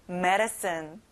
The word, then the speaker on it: medicine: 자, D 발음이 안 나요. soft하게 르로 발음해 보세요. medicine.